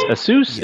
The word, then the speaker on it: Asus: I was thinking as a laptop I was going to get, I don't know if I pronounce it, Asus, A-S-U-S? – Mm-hmm.